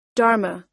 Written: dharma

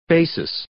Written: basis

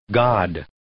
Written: god

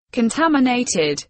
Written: contaminated

